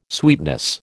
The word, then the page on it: sweetness: American English